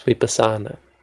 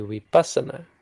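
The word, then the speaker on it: vipassanā: So it's vipassana. But this should be vipassana. Adisakkara. It really makes a difference. It's more than you'd realize. It changes the word.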